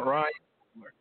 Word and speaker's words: Ryan Coogler: Ryan Coogler. Am I saying it right? – Yeah. – Ryan Coogler.